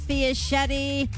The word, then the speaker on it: Fiaschetti: This is Bernadette Fiaschetti. That rhymes with spaghetti.